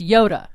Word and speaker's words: Iota: That would be Iota, pronounced Yoda.